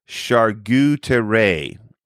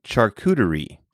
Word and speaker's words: charcuterie: And you said on their menu that they offered a, and I'm going to pronounce this like you pronounced it, a charcuterie. And you didn't know what that was. In all honesty, Taebyn, it's pronounced charcuterie.